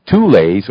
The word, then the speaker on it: Thule: Now, in Tibet, they were known as the Thules, or the T-U-L-E's, take the H out. The Germans spell it with an H, T-H-U-L-E, or the Thulas.